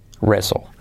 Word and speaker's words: wrestle: Con una pizquita de L al final. No sería wrestle, sino wrestle, wrestle. Luchar, wrestle. La T no se pronuncia.